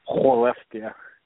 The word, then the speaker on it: Skellefteå: No, the team's called the Skellefteå. So, Skellefteå. – Some people say Skellefteå. If you say it like that, that's how the town is pronounced.